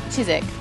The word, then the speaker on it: Chiswick: Am I saying it right? Chiswick. – Yeah, you are. Chiswick. – Chiswick. – You don't say the w. Chiswick. – Well, it's like the Smithwick, the beer.